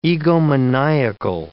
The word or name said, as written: egomaniacal